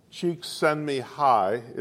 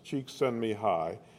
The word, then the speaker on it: Csikszentmihalyi: Now, a psychologist with a Hungarian name, which I have difficulty pronouncing, Csikszentmihalyi is the way they say it should be pronounced, Csikszentmihalyi.